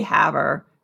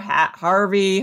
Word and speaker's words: Havre: There was a city called Havre, and make sure you say Haver and not like Haver, Harvey, Harvard.